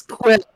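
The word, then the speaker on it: Kwyl: It's Quill. – Might as well. Might as well give Quill a try. – Introducing the new porcupine. Maximal Quill. – But it's spelled weird. It's got a Y in there somewhere. – It's K-W-Y-L.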